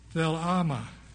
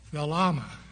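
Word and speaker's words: thelema: But this word pleasure in the Greek is spelled T-H-E-L-E-M-A. T-H-E-L-E-M-A, thelama, thelama. And it means will. 62 times this word is translated will, W-I-L-L, in the New Testament.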